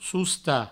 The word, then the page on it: sostar: sousta